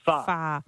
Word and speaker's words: pho: And it's the same with the Vietnamese noodle soup, which it's P-H-O, so you're like, oh, can I please have a pho? – Yeah. – And then you get laughed at, and you're told, no, it's pronounced Far. – It is far.